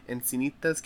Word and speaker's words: Encinitas: Yeah, Encinitas, California.